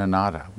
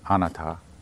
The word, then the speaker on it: anatta: I, I have to confess I didn't study Pali in any formal way, so I sort of it's catch as catch can on pronunciation here. I believe it's anatta. That's pretty much the most obscure of the teachings.